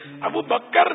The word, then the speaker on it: ابو بکر: ابو بکر نہیں ہے کاف ساکن ہے ابو بکر صحیح تلفظ ہے ابو بکر.